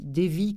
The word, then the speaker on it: Devi: Devi, comme ça se prononce, D-E-V-I. Ananda, A-N-A-N-D-A, D-E-V-I.